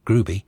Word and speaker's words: Groby: And, yeah, Groovy Village, spelt Groby, pronounced Groovy.